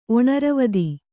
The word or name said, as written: ಒಣರವದಿ